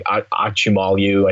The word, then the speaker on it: Acemoglu: Like if you read like Why Nations Fail, which is by Darren, I can never say his name right, Acemoglu, I think is the way you pronounce it, and James Robinson, like that's what they argue, right?